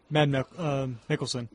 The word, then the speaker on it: Mads Mikkelsen: Even though Norman Reedus and Guillermo del Toro are involved in it, and Mads Mikkelsen, is that how you pronounce his name? – Mads Mikkelsen.